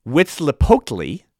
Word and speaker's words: Huitzilopochtli: The sun god, pronounced Huitzilopochtli, was waging a constant war against darkness.